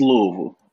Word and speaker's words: Louisville: It's Louisville. – Okay, Louisville. They got put too much emphasis on the Louis. It's just Louisville. Just let it flow.